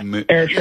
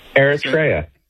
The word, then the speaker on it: Eritrea: Well, there's an African country just north of Ethiopia, and it's spelled E-R-I-T-R-E-A. – Yeah, Eritrea. Yeah. – How would you say that? – Eritrea.